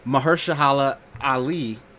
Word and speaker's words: Mahershala Ali: And they cast Mahershala Ali.